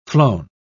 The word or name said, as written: flown